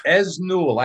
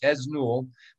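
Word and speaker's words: Esnault: Esnul. I know. You don't pronounce the T in French. Esnul.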